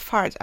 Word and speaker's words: FRT: Und äh, im Englischen heißt es Fast Repetitive Tick und abgekürzt ist das FRT und im Englischen wird das Fart, also Furz ausgesprochen.